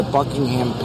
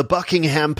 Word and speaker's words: Buckingham: I mean, they're absolutely living up to the stereotype of like older American tourists in London, because they call it the Buckingham Palace. And in England, we call it Buckingham Palace. So we don't actually pronounce the H in the name, which is an easy mistake to make.